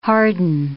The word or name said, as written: harden